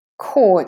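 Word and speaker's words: corps: Core, it's a French word, and we don't say the P or the S there, actually.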